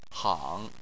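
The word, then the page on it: hang: tone 3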